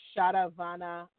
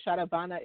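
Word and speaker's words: Sharavana: So the Shatavana is spelled with an S-H-A-R-A-V as in Victor, A-N as in Nancy, A. So Shatavana, and then Bhavaya, B-H-A-V as in Victor, A-Y-A, Bhavaya.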